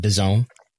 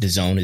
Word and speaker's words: DAZN: whether it's BN Sport or DAZN, not DAZN, but DAZN. – Confusing branding there, but DAZN is the pronunciation apparently.